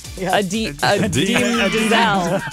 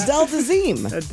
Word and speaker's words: Idina Menzel: Adina Menzel. We can't even remember her mispronounced. – Adele Dazeem.